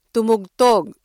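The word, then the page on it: tumugtog: Filipino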